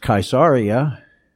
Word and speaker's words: Caesarea: That word Caesarea in Greek is actually pronounced Caesarea, and it's the word from which we get the word Kaiser.